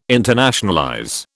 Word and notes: internationalize: British English

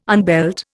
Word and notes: unbelt: Indian

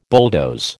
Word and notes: bulldoze: American English

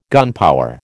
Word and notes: gunpower: American English

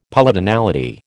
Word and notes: Polytonality: American English

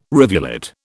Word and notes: rivulet: British English

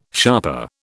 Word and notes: sharper: British English

